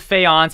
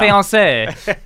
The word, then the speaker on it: faience: Not fiance.